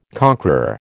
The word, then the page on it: conqueror: American English